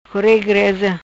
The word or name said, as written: Core Greza